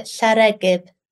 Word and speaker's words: Llareggub: So tell me how to pronounce it. – You pronounce it Llareggub.